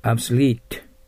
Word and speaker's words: obsolete: これは後ろにアクセントがあるから obsolete がいいですね。発音機を見てください。はい、 obsolete、廃れているという意味です。